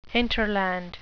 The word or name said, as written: hinterland